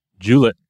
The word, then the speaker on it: Juliet: This is Juliet, or Juliet, right? If you're depending on how you're, what line from the play you're using, sometimes you get to hit two syllables instead of three on that.